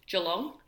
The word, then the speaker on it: Geelong: Geelong.